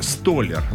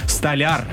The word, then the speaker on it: столяр: Ну, я вам так скажу, на разных предприятиях, где они работают, они как раз-таки предпочитают говорить «столяр». Ну, это такой профессиональный сленг. Но все-таки в словарях зафиксирована версия «столяр». Вот так правильно говорить.